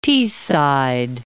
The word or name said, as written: Teesside